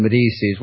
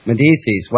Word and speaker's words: Medici: But I would have to say that as Medici, you know, it's like fettuccine, fettuccine Alfredo, burro dolce.